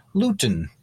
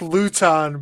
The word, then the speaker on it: Luton: You only like Jake Luton, Brad, because it rhymes with futon, and now you're thinking about the college days again.